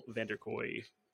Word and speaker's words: Vander Kooy: Today, we have Daniel Vanderkooy. I'm pretty sure I pronounced your name correctly. – Yeah, you got it.